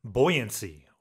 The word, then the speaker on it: buoyancy: What's interesting though, pronunciation-wise, a buoy has buoyancy or is buoyant, but in that case, you pronounce it boy.